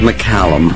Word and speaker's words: McCallum: It's David McCallum. Get the name right. – David McCallum is here. I always have called him David McCullum, you know, but he's David McCallum. – David McCallum, quite right. – Yeah, it's yeah. Like California.